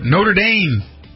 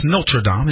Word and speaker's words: Notre Dame: Notre Dame. That's the way we pronounce it here in the United States, but it's Notre Dame.